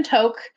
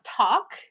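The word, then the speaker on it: Tok: So I grew up in Toke. Yeah, weird name, I know. a lot of people pronounce it as talk, because it's T-O-K.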